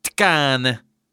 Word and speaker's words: Tkane: Tänk att man uttalar det med T-K-A-N-E. Tkane. Tkane.